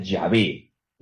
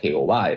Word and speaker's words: Yahvé: Se pronuncia Yahvé. No se dice Jehová, hermanos. Se dice Yahvé.